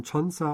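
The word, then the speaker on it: Chonsa (천사): Da ist mir zum Beispiel bekannt, dass also 1004, das ist dann auf Koreanisch ausgesprochen Chonsa und das ist dann auch ein Engel.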